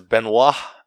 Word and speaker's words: Benoit: Because Benoit, or B-N-O, or B-E-N-O-I-T, that's how it's pronounced, but it, like, reminds me of, like, Benoit balls.